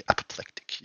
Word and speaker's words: apoplectic: Apoplectic frenzy. I never know how to pronounce that word. – Apoplectic. Yeah. – There you go.